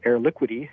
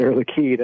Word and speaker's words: Air Liquide: If you were to look at it, it would be spelled Air Liquidy. – Mm-hmm. – It's pronounced Air, right. – Air Liquide.